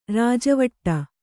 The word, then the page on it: ರಾಜವಾಡೆ: rāja vāḍe